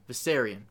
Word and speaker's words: Viserion: Dany and Drogon get hit by Viseron's blue flame. Am I saying his name right? Viseron? – Viserion? – Viserion. I'm sorry.